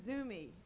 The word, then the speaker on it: Zumi: And one thing you need to know about this song is the word Zumi appears in the song. And that's Wisconsinese for weird person. It rhymes with loony.